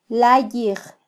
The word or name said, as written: layij